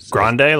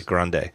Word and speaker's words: Grande: Lucida Grande is Grande, like when you order from Starbucks? – Yeah, Grande. Was going to be,